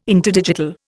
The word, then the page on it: interdigital: Indian